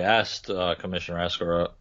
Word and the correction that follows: Oresco: I just really butchered his name there, Commissioner Oresco, about this.